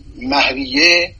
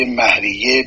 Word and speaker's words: مهریه: اما مهریه، من این رو اصلاح بکنم، مهر به کار برده نمیشه، تلفظ مهر درست نیست، مه از و مهریه. همه اساتید ما و قضات دادگاه و حقوقدان‌ها این کلمه را به صورت مهریه بر وزن شهریه تلفظ میکنن.